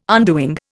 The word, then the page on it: undoing: Indian